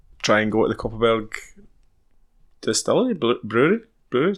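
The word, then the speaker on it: Kopparberg: You said it wrong the first day, I think. – Copperberg. – Yeah, you said Cockerberg first.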